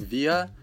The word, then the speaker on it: wir: По поводу произношения местоимений «виа» и ия мы «вы» люди, которые учили немецкий в школе, часто говорят, что вот нас учили говорить просто «вир», «ир». Но, ну, как вы понимаете, в школах не всегда э, уделяют внимание лучшему варианту произношения, поэтому на самом деле должны эти местоимения произноситься именно так via, «я».